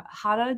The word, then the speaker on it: Rada: So I went down to San Diego and I stopped in at, I think you pronounce it, Rada Jiu-Jitsu. It's spelled R-A-D-A. But I think it's a little, we've got the silent R situation.